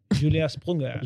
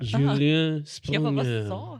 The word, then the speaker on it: Julien Sprunger: Och jag vill uttala det korrekt bara så jag använder mig av lite prospect som är mycket bra källa. – Ja. – Julien Sprunger. – Julien Sprunger.